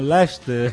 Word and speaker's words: Leicester: Se pronuncia Lester. Como o nome do ratinho ajudante do mundo de Bigman, Lester.